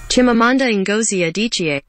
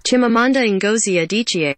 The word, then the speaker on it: Chimamanda Ngozi Adichie: Chimamanda Ngozi Adichie. – Say it, play it one more time. – Chimamanda Ngozi Adichie.